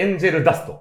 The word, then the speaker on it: 天使の涙: これです劇場版「シティーハンターエンジェルダスト」これ、天使の涙と書いて、エンジェルダス。